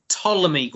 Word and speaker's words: Ptolemy: Next up, we have got The Last Days of Ptolemy Gray, it's pronounced, even though there's a extra P in Ptolemy.